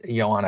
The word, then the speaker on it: Yoan: It's it's spelled like Joan, but it is pronounced Yoan.